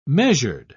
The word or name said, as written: measured